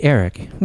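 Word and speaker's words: Aric: My name begins with an A, Eric, not E-R-I-C, A-R-I-C.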